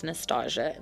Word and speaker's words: Nastahja: Or you can follow me at NUH_STA_JA. That's how you pronounce my name. That's Nastasia.